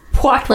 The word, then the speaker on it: Poirot: Poirot. Not Hercules Poirot. Poirot.